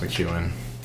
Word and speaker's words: McKeon: I know even Coach Harbaugh got it wrong a little bit, but it's Irish, so it's pronounced McKeon.